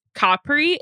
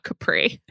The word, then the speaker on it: Capri: And so the location is Capri, Italy. And yes, that's how you say it. I have a friend whose family is from Capri, so I've been corrected. It's not Capri.